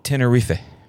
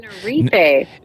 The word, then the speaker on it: Tenerife: And it is 100% Tenerife. That's how they say it. – Tenerife.